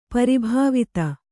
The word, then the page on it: ಪ್ರಭಾವಿತೆ: Kannada